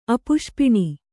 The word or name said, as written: ಅಪುಷ್ಪಿಣಿ